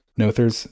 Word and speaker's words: Noether: Noether's theorem.